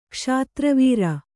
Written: ಕ್ಷಾತ್ರವೀರ

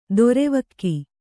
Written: ದ್ರಾವಕ

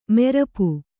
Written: ಮಾರಾಪು

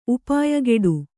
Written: ಉಪಾಯಗೆಡು

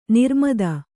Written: ನಿರ್ಮದೆ

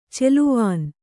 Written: ಚೆಲುವಾನ್

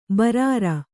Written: ಬೇರೂರು

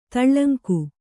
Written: ತೇಲುಂಗ

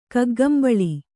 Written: ಕಗ್ಗಂಬಳಿ

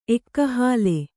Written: ಎಕ್ಕಹಾಲೆ

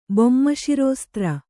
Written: ಬೊಮ್ಮಶಿರೋಸ್ತ್ರ

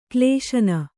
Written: ಕ್ಲೇಶನ

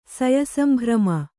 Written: ಸಯಸಂಭ್ರಮ

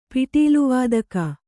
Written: ಪಿಟೀಲುವಾದಕ

